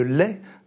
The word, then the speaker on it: legs: Et là, on retrouve la phrase fameuse de Renan sur le « lait », le « g » ne se prononce pas, « l-e-g-s », la possession en commun d'un riche lait de souvenirs.